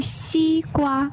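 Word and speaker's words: xi gua (西瓜): It's actually xi gua. – Xi gua. – Yes. – Xi gua. No, you can tell the pronunciation perfectly. Xi gua. Oh, it's the same intonation.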